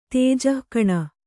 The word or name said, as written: ತೇಜಃಕಣ